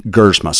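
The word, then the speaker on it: Gerzema: Now, when you see his name, it's going to look like it's John Gersma. That's the way it's spelled. It's pronounced like with the silent E, Gersma.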